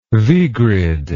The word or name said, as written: vigrid